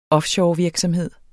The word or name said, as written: offshorevirksomhed